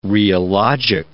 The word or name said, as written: rheologic